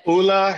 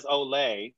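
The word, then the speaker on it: Ole: Yeah, I pronounced it as Olay until we met the brand. And we had this whole onboarding call with them. And I said Olay about 400 times. And then at the end of the call, they're like, by the way, it's pronounced Ulla.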